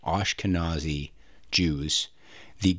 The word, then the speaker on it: Ashkenazi: Did you know that among Ashkenazi Jews, sorry, I keep saying that wrong, Ashkenazi Jews, the golden peacock is a symbol for joy and creativity.